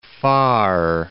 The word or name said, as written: Pharr